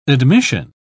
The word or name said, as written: admission